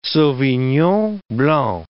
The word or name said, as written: sauvignon blanc